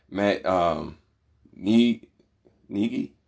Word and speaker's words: Matt Nagy: My next takeaway is I don't know if I said his name right as far as the coach from Chicago Bears, Matt Nagy.